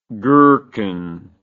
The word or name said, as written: gerkin